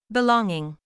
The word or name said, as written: belonging